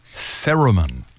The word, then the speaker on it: cerumen: CERUMEN. – Not Saruman, like out of Lord of the Rings.